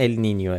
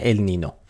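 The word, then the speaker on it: El Niño: ولی تلفظ درست اگه میخواین ال نینیو، ولی همه جا تو فارسی بچرخید کسی چیزی نمیفهمه. ال نینیو همه جا نوشتن ال نینو.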